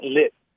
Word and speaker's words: lit: Like they'll say, like, c'est vraiment lit. And like, it's really lit tonight. – They pronounce the T.